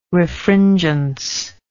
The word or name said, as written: refringence